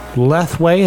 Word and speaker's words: Lethwei: Lethwei is how you pronounce it? – Lethwei. Lethwei. – I didn't know how to pronounce it.